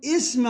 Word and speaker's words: اسم: اسم، نقول سبح اسم ربك الأعلى، لاحظوا لا تلفظ عند الوصل، سبح اسم لا وجود لها، لكن عند الابتداء بها نحولها إلى همزة قطع ونكسرها، فنقول اسم ربك الأعلى، اسم ربك الأعلى.